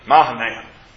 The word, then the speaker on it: Mahanaim: Mahanaim is acceptable in English? – Yeah, except for the long A at the beginning. – Oh, Mahanaim.